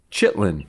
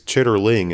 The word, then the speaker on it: chitterling: It is, but it's chitterling is actually... Oh. How you would write it out and spell it and Thank you. – Chitlin, that's the accent. That's the dialect.